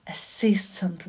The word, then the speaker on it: assistant: Assistant. Ударение на второй слог падает. Assistant.